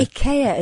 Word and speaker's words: IKEA: Or IKEA, as they're trying to get us to pronounce it. I'm never going to do it that way. – Are they trying to get you to do that now? – Yeah. – Because Ned Sheeran pronounced it like that about 20 years ago on Radio 4. – Well, that's the correct pronunciation.